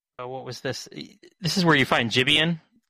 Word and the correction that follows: Jibanyan: You pronounce that one, Wheels. – Jibanyan. – Sorry.